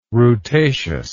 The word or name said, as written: rutaceous